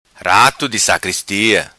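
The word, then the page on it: Rato Di Sacristia: patua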